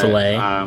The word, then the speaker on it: Philae: Just Philae.